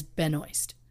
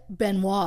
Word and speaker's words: Benoist: And she says Benoist. And I was telling Lauren this earlier. The only one I could find quickly that was saying her name, it was a James Corden show. And she said, like, he was like, what are some of the mishearings that you, like, what are some of the mispronunciations you hear? And she's like, oh, we get Benoit a lot.